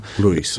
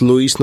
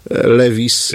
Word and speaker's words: Lewis: Różne formy wymawiania tego nazwiska są. Chyba poprawna jest właśnie ta, którą powiedział. Lewis. – No, oczywiście oryginalna jest Lewis, natomiast w Polsce przed wojną na przykład mówiło się Lewis.